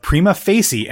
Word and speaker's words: Prima Facie: Uh, we'll get into that here in a second, but it was announced exclusively through Variety that a movie adaptation of Prima Facie, and I did get confirmation that that is probably how you should be pronouncing it.